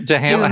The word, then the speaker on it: Duhamel: Duhamel. How do you say it? – Duhamel. – Duhamel. – Duhamel. – It's not Duhamel. It's Duhamel. It's Duhamel.